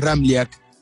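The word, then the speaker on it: Ramljak: Es un poco complicado porque tiene tres consonantes juntas y la J se pronuncia como una I. Bien pronunciado, el apellido es Ramliak.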